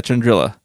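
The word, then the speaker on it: Chandrila: So Toryn Farr, her story starts, well, with her birth, but she was born and raised on the planet Chandrilla, I think is how you say it. Chandrilla? Yeah, Chandrilla. There's no Y next to that A.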